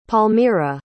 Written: palmira

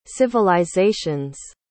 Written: civilizations

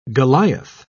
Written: goliath

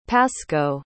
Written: pascoe

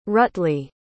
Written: rutley

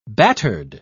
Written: battered

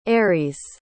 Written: ares

